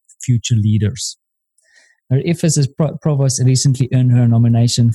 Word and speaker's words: provost: Kelly is also a provost.